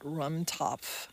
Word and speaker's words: Rumtopf: I didn't tell him he was going to be talking about Rumtopf. Rumtopf is spelled R-U-M-T-O-P-F.